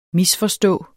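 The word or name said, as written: MISFORSTÅS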